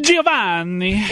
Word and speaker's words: Giovanni: Do you want to go ahead and hit me with the Italian pronunciation? – Giovanni. – Exactly right.